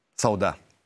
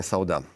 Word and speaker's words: Сауда: Сауда, простите, пожалуйста, опять я ошибся. Дмитрий вот уже мне даже отдельно написал ударение. Дмитрий, простите, Верхняя Сауда.